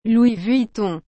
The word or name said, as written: Louis Vuitton